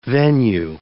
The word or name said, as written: venue